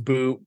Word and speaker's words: But: Like even a guy that could go in the first round, Daniil Butt. Like, yeah, he was on my list. – It's pronounced boot, but whatever.